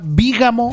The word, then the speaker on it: bígamo: ¿Han escuchado en algún momento de su vida la palabra bígamo? Sí, con acento en la I.